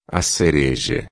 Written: cereja

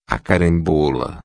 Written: carambola

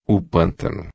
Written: pantano

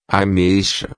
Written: ameixa

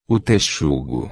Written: texugo

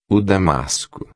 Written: damasco

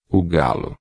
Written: galo

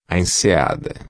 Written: enseada

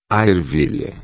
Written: ervilha

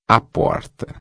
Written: porta

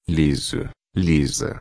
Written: liso-a

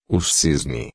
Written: cisne

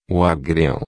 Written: agriao

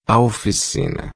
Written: oficina

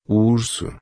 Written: urso

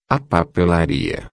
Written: papelaria

